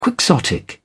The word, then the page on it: quixotic: British English